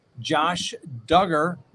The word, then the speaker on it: Duggar: First, Josh Duggar, rhymes with hugger, wants to delay his trial.